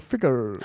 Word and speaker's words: Figure: Figure, also nicht Ficker, sondern Figure.